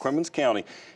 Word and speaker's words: Perquimans: Did I pronounce that right? – Yes. – Perquimans County.